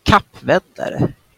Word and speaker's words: Kappvändare: So how is it pronounced in Swedish? Kappvändare.